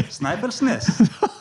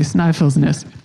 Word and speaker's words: Snæfellsnes: Okay, and we are on the, I'm so sorry for my pronunciations, but the Snæfellsnes Peninsula. – Close enough. – Okay. – Snæfellsnes. – Okay, I did terribly, Snæfellsnes.